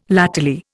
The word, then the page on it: latterly: Indian